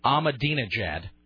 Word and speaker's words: Ahmadinejad: Ahmadinejad.